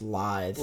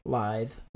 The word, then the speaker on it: lithe: I think that word is pronounced lithe. – Lithe? – In English. – Okay. – Lithe. – Lithe. Yeah. Thank you.